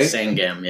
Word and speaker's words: Sangam: That's the one in Amar Siva Sangma. Am I saying that right? – Sangam, yeah. – Sangman? – Sangam. It's S-A-N-G-A-M, I think.